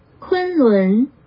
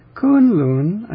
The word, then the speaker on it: Kunlun: Kunlun. – Kunlun. Kunlun. Oh, it's rising on the second syllable and high on the first. I didn't know that. Kunlun. I had that backwards.